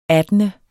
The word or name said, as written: attende